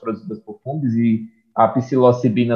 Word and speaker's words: Psilocibina: Psilocibina.